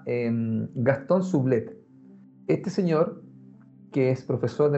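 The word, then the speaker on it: Soublette: Gastón, Sublet. – Sublet se escribe S-O-U, B L-E-T-T-E. E. Gastón Sublet.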